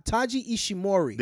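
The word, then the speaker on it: Taiji Ishimori: Taji, oh no, is it Ta, Taji Ishimori. There you go. That's how you say it.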